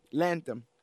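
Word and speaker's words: Lantom: I had to look at the spelling because I always say it wrong. Lantham.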